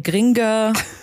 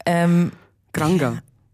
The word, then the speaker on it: Granger: Hermine Gringer. – Granger. – Nein. – Aber sie heißt ja Granger. – Granger, genau, nicht Gringer.